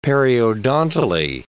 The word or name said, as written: periodontally